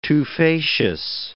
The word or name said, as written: tufaceous